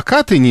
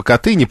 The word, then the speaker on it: Катынь: Вы полагаете, что если бы, к примеру, вот как по Катыни или по Катыни, по-разному ставят ударение,